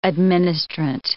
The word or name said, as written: administrant